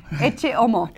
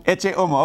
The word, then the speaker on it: Ecce Homo: How do you say Ecce Homo? – Ecce Homo. – Ecce Homo, okay.